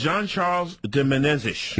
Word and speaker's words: Jean-Charles de Menezes: His name is Jean-Charles de Menezes.